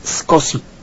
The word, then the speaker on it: SCSI: A una computadora grande le puede usted poner un tipo de disco duro muy particular que utiliza una técnica, un protocolo que se llama SCSI, se pronuncia SCSI.